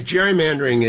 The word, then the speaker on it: gerrymandering: One is gerrymandering is routinely mispronounced. It's gerrymandering, but I'm a scold when I bring that up.